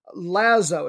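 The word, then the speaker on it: Wlazło: On the scoring leaderboard, Piotr Lazo, it's got a silent W on the front if you end up looking up a box score.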